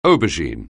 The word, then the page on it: aubergine: anglais